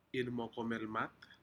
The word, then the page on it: inmokomelmat: Aneityum